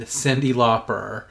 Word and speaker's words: Cyndi Lauper: But they said Cyndi Lauper, and then in parentheses it said rhymes with popper.